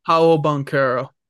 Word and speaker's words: Paolo Banchero: Um, pa- Paulo Paulo Blanchero, yeah. – Paolo Banchero.